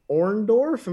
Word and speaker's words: Orndorff: We got WrestleMania 1, Hulk Hogan and Mr. T versus Roddy Piper and Orndorff.